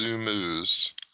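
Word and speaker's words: Zoomooz: And then the really interesting one I thought was one, I'm not even sure how you pronounce it, but it's Zoomooze, like Zoom and then O-O-Z, Zoomooze.js, that will basically allow you to zoom to any element in the DOM.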